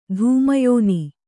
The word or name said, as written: ಧೂಮಯೋನಿ